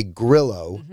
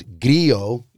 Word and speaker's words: Grillo: Well, I guess if you're an American, you'd say Grillo, but it's actually pronounced Grillo in Italian.